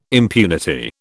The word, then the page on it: impunity: British English